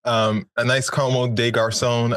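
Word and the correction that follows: Comme des Garçons: Uh, is that how you pronounce that? Um, no, it's Comme de Garcon. It's a French there we go.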